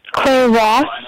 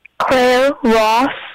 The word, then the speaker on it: Claire Ross: Can you spell your name? I want to say it correctly. – C-L-A-I-R-E. – Oh, Claire. Okay. It's a little hard to understand you when you're saying it.